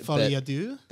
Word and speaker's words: Folie à Deux: Folie à Deux.